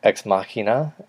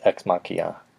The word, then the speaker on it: Ex Machina: Ex Machina. Machina. – I've never heard you say it like that. – That's how it's pronounced. People say Ex Machina, but it's Ex Machina.